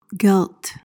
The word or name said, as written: gelt